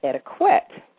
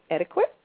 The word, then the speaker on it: etiquette: And of course, understand why, because it's spelled E-T-I-Q-U-E-T-T-E, and the Q, yes, does make the cool sound. But since this word came from the French word that means ticket, I always say a great way to remember how to pronounce the word is to say the word slowly, and you will hear yourself say ticket.